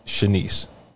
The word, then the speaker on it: Chinese: The name is spelled Chinese. – C-H-I-N-E-S-E. Wait a minute. – Pronounced Shanice.